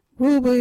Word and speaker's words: Ruubu: our local Wadjuri word is Ruubu. What is a Ruubu? Ruubu is a word for rope.